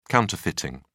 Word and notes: counterfeiting: British English

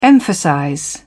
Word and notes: emphasize: British English

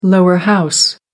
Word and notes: lower house: American English

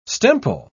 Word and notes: stemple: British English

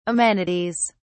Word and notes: amenities: British English

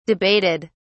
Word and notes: debated: British English

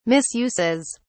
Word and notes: misuses: British English

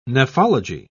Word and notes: nephology: British English

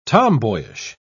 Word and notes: tomboyish: British English

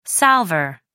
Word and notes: salvor: American English

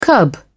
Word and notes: cub: British English

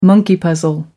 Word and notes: monkey puzzle: American English

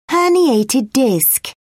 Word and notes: herniated disc: British English

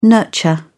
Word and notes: nurture: British English

